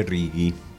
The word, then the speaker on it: Rigi: Les voy a hablar en todo caso del monte reggae. Vamos a pronunciarlo mejor, del monte reggae.